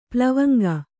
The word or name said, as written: ಪ್ಲವಂಗ